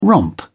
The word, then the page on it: romp: British English